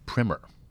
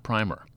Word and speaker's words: primer: she wrote in to let us know it's actually pronounced primer, not primer.